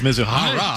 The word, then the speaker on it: Mizuhara: I think it's Mizuhara.